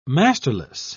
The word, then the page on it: masterless: British English